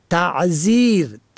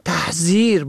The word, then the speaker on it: تعذیر: واژه دیگر تعذیر ت عین زال یا ر به معنای عذرآورنده را تحذیر با مفاهیم ترساندن و برحذر داشتن اشتباه می گیرند و بیشتر سخنرانان نادرست تلفظ می کنند.